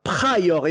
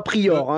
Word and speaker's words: Pryor: Et encore, j'ai fait l'effort de dire Pryor et non pas Prior, hein, quand même.